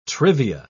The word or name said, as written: trivia